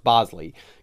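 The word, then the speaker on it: Boushley: But Caleb Bosley, it looks like Bushley, but it's pronounced Bosley, gets promoted by Milwaukee.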